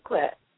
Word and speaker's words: Ogunquit: Oh, Ogunquit.